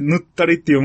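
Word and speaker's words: 沼垂: 塗ったり。 – 塗ったり。 – 水ですよね。だから、うん、垂直とかの、そうそう、垂直とかの水。 – うん。 – 沼が垂直になってるみたいな。 – やばくない？そこ行かない方がいいんじゃない？非常に危険性が。 – 沼水と書いて、うん、沼水と書いて。 – 沼水と書いて、塗ったりって読むから、うん、非常にだから地名にありがちな、うん、難読地名ってやつですよね。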